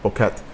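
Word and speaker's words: Boquete: So when you head out there, there's this little town called Boqueta or something like that. That's how you say it.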